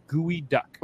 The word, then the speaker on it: geoduck: It's pronounced gooey duck.